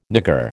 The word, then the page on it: nigger: American English